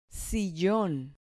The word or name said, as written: sillon